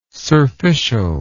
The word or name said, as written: surficial